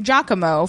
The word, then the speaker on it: Giacomo: Number four, in Italian, the letter I after G softens the sound. So yes, Victor Colliano's uncle Joe is pronounced just like Joe. Puccini's first name, Giacomo, follows the same rule. So Giacomo's nickname would sound like the French name Jacques or Jacques.